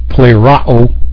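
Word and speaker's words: pleirao (πληρόω): Yep, that's the pronunciation is pleirao. The two O's are both used, and the first one is an omicron, okay, and it's pronounced like an ah, okay, and then the last one is an omega, that's a long O.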